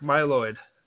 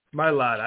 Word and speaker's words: Mylod: Mark Myloid. Mylod.